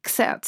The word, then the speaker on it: xertz: I pronounce that with a kind of German accent. Xertz. So X-E-R-T-Z. – X-E-R-T-Z.